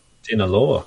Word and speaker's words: Sinaloa: Sinaloa cartel.